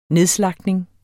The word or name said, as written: nedslagtning